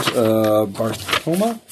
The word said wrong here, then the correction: Baftoma: Yeah. Baftoma. Sorry. Baftoma.